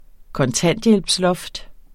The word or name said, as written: kontanthjælpsloft